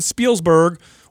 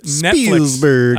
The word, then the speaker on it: Spielberg: And then we talked about Steven Spielberg working with Spielberg.